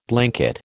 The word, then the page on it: blanket: American English